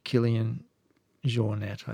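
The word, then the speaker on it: Kilian Jornet: And it's she, if I've told you about Killian Jornet, I think would probably be how you pronounce his name.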